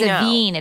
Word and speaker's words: Avène: But other than that, I'm using all, and I'm going to say it wrong, Avène? No, yes, not Aveeno. Yes, not Aveeno. – It's Avène. It's like a French brand, but it's like, A-V-E-N-E? – Yeah, with a little, maybe an accent.